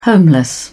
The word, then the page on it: homeless: British English